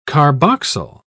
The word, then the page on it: carboxyl: British English